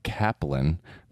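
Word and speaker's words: Cappelen: Sebastian told me they pronounce it Kaplan, which is Danish because Sebastian is from Denmark and we played today.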